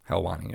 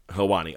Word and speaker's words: Helwani: Hence why the moment that, and I'll maybe butcher the name, but Ariel Helwani. – Helwani, yep. – Is Helwani, okay.